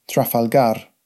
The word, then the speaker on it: Trafalgar: This was originally called Standlinch House, but since 1815 has been known as Trafalgar House, pronounced locally, as in Spanish, Trafalgar.